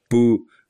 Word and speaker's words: Pooh: Причем Pooh, он же P-O-H. Да, так пишется. Читается практически как пу, в смысле какашка. Нет, что это такое?